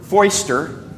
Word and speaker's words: Foister: Foister rhymes with oyster.